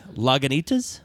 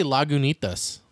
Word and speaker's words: Lagunitas: Lagunitas. – Is that how you say it? – Uh-huh. – I say Lagunitas.